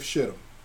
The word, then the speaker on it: Shittim: There's a passage in the Bible, forgive me for not knowing it immediately, that talks about the land of Shittim. That's how it's pronounced.